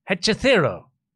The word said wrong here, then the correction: Hechicero: Yeah, close. Hechicero. – That's what I said. – Yeah, but I don't know. You put emphasis on the wrong syllable or something.